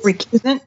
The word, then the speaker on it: recusant: And in order, so you wouldn't have to pay the, am I saying this right? Recusant fees? – Recusant? – There you go.